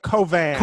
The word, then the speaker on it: Cauvin: Closer, Cauvin. – Cauvin. – Even though it's V-I-N.